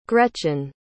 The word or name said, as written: gretchen